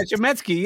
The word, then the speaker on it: Chametsky: Yeah, Chametsky.